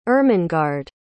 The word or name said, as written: Ermengarde